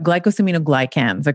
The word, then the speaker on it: glycosaminoglycans: Of course, it's also going to be good for your joints, your tendons, and your ligaments because of glumino. I will say this wrong. So you got to, I like GAG for short, but glycosaminoglycans.